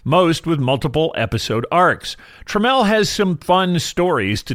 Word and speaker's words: Tramell: Seth Milchick is being played by Tramell Tillman, one of the breakout stars of the series. I always want to Midwesternize his name and call him Tramell. It's pronounced Tramell.